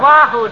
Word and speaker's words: Oahu: Lying on the sand at Oahu. – That's pronounced Wahoo, dearie.